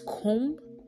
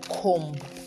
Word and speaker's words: comb: It's comb and not comb. A lot of people say comb your hair. They bombed the place. So it is bomb and comb. The B is silent.